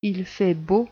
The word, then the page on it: il fait beau: French